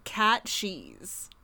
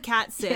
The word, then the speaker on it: cat sith: We are going to be talking about the Scottish folk creature Cat Cheese. And it is spelled cat, C-A-T, and then S-I-T-H. So it looks like cat sit, yes, but it's pronounced cat cheese.